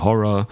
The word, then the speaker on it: Ho99o9: Apropos glitchy Hip-Hop, wir fahren hier fort mit der Band Horror, nur echt geschrieben mit 9 anstatt vom R, also Ho99O9, ausgesprochen wird es auf jeden Fall Horror.